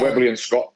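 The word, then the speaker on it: Webley & Scott: And something else, too, is you recently helped out with a sponsorship from, I think I'm saying this correctly, is it Webley and Scott? – Yeah, Webley and Scott.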